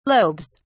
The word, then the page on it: lobes: British English